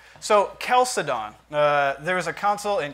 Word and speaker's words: Chalcedon: I believe it should be properly pronounced Chalcedon. Chalcedon. – Part CH like chasm.